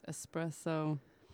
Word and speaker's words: espresso: It's espresso. – It's pronounced espresso. – Yeah. E-S. That's it. No X. – No X. There's no X in there.